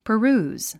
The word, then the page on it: peruse: pərúːz; パルーズ